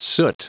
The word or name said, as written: soot